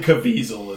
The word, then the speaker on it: Caviezel: Jim Caviezel is how you pronounce.